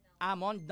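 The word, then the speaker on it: almond: Amond, no? Silent L.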